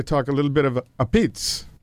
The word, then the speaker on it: apizza: So I enjoyed this interview to hear you and Brian go back and forth on the pronunciation of the true Italian pronunciation of pizza. – Right. – A pizza. – Well, that's actually the New Haven pronunciation.